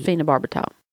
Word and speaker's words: phenobarbital: Where you get strapped to the gurney and injected with phenobarbital. How do you say that? – I think you did it right.